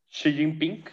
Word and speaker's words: Xi Jinping: Xi Jinping, si es que lo pronuncié correctamente. – Exactamente. No, perfecto. Pareces chino.